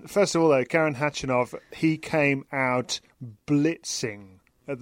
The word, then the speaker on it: Khachanov: Catherine, first of all, we're going to hear, as I said, from Andy Murray talking to you after his victory over Karen Khachanov a little bit later in this show.